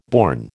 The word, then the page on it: born: American English